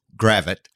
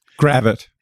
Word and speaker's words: Gravette: Tell me about what growing up in Gravette, is that how it's pronounced? – Actually, it's Gravette. – Gravette. You see, that's why I ask.